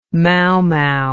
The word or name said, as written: mau mau